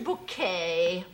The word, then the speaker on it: Bucket: That's spelled B-U-C-K-E-T. – Oh, bucket. Oh, yes, we have a bucket. – Pronounced bouquet, dear.